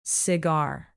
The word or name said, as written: cigar